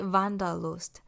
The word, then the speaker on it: Wanderlust: 这个字德文跟英文的发音不一样，德文的话呢，就是像刚刚讲的 Wanderlust，那英文的话呢，则是 Wanderlust，Wanderlust。德文 Die Wanderlust。